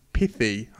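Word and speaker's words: Pithie: Those three races were won respectively by Juan Sebastian Molano of UAE, Axel Zangler of Cofidis, and Laurence Pithy. I was tempted to pronounce that in a French way, but he's a New Zealander, and he rides for Groupama.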